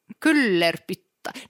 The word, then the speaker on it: Kyllerpyttaa: Det stavas ju med liksom finska uttalsregler med y. – Ja, ja. – Så det blir u. – K-Y-L-L-E-R-P-Y-T-T-A-A. Kullerpytta.